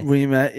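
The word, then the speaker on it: Ouimet: So obviously, you had Francis O'May, didn't we? – Yeah. – That isn't how to say his surname. – Do you know what? – We met, is it? – We met, yeah.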